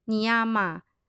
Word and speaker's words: niyama: 八部功法的第二步精进，它的范文读作尼亚玛（N I Y A M A），是我们应该要积极培养与拥有的美好特质。